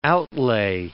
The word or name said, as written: outlay